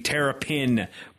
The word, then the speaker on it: Terrapin: Tell us a little bit about the fundraising there with the Terrapin pool. And I'm putting the emphasis on the pin there for a reason.